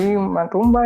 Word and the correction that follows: Mulumba: ¿eh? – Aquí Mulumba. Mulumba.